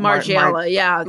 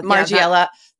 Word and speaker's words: Margiela: There was the Margiela. Well, I don't know how to pronounce that. – Margiela. – Margiela.